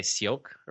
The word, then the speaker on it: Ciocc: You know, because those Concords were built by Cioc or whatever, C-I-O-C-C. – Isn't that Cioc? – Cioc. I never know how to pronounce it.